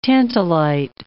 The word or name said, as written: tantalite